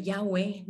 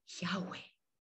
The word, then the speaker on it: Yahweh: El nombre de Yahweh no tiene ninguna pronunciación tosca como la K, la R, la T, la M. Es solamente un soplido, Yahweh.